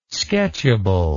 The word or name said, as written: sketchable